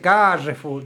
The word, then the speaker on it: Carrefour: La E no la pronuncies. Uh-huh. Carrefour. – Listo. Ahí está. – No, se dice Carrefour. No se dice así.